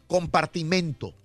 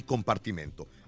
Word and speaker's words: compartimento: Bueno, entonces, no solamente para corroborarlo, es beneficencia y Compartimiento Órale. – No se dice beneficencia ni compartimiento, es beneficencia y compartimento.